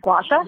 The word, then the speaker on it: Gua Sha: Gua Sha? – Yeah, you said it right.